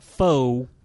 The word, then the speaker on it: pho: Yeah, I've also been eating a lot at the pho grill that's... – You just said it wrong! Pho!